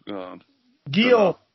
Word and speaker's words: Guillaume: And the other horses in behind, I tell you what, Port Guillaume was very disappointing for Jean-Claude Rouget. – That's the worst French pronunciation you've ever come out with. – What did they call him, Port Guillaume? – Guillaume.